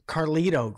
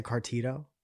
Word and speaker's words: Carlito: Yeah, you said Cartito.